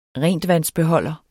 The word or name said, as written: rentvandsbeholder